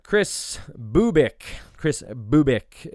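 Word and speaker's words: Kris Bubic: Chris Bubik is the pronunciation.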